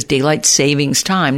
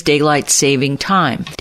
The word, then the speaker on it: daylight saving time: For years, I always mispronounced the time change as daylight savings time. Now, there's no S in saving. It is daylight saving time.